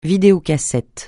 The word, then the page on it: vidéocassette: francais